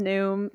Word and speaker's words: Noom: It rhymes with Loom. It's called Noom.